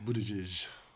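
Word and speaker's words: Buttigieg: And so, Miss Pete and Booty Judge. – I never knew how to pronounce his name. – It's Booty Judge.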